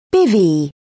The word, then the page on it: bivvy: British English